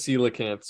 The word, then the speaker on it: coelacanth: It is coelacanth.